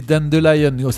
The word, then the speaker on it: Dandelion: Le pissenlit en anglais, c'est Dandelion. Ça pourrait se prononcer Dandelion si on le lisait à la française. Dandelion.